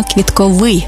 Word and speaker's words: квітковий: До речі, правильний наголос саме квітковий, бо стосується квітів. А квітковий – це про органи розмноження за допомогою квіток.